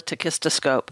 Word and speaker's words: tachistoscope: It's called a tachistoscope.